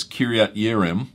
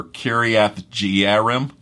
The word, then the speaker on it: Kiryat Yearim: You may know it as Kiryat Yearim or Kiryath Jearim, you might pronounce it, where the Ark of the Covenant was stored for some years.